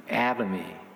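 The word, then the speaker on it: Atomy: 아마 요즘 집집마다 여러분의 가정에 아토미 제품 있는 가정들이 좀 있을 거예요. 어쩌면 많을 겁니다. 영어로는 그냥 애터미 이렇게 발음하나요? 애터미. 예. 우리 한국말로는 아토미 이래가지고 아토미 제품인데, 아토미는 생활 필수품을 파는 글로벌 유통업체로 치약과 칫솔, 아토미 치약 칫솔 있잖아요.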